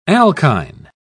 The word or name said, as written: alkyne